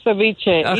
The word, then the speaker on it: ceviche: Tell us about the shrimp ceviche. Did I say that right? Probably not. – Yes, shrimp ceviche.